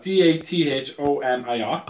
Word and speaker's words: Dathomir: I've spelled out D A T H O M I R.